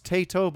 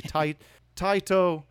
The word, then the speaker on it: Taito: All right, so in 1988, Taito, maybe some people pronounce this Taito, but the Japanese would be Taito, released Superman the arcade game.